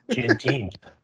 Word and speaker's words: Juneteenth: Juneteenth.